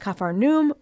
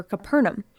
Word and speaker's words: Capernaum: We're headed to Capernaum. You might also hear it pronounced Capharnaum or Capernaum.